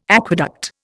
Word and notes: aqueduct: Indian

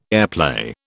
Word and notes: airplay: British English

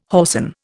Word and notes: whoreson: Indian

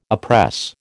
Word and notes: oppress: American English

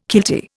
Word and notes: kiltie: Indian

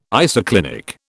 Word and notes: isoclinic: British English

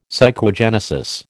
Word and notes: psychogenesis: American English

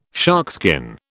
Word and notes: sharkskin: British English

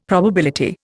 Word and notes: probability: Indian

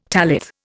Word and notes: tallith: Indian

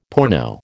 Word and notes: porno: American English

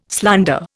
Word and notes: slander: Indian

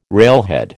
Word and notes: railhead: American English

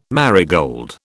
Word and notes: marigold: British English

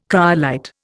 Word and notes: cryolite: Indian

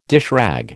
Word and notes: dishrag: American English

